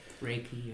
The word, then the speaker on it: Reiki: A photography student, Reiki. Is that how you say it? Reiki? – Reiki? – Reiki, yeah.